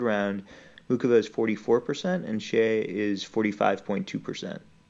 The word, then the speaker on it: Xie: Mukova and Xie, how do you say it? Shea.